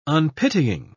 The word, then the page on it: unpitying: British English